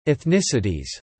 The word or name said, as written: ethnicities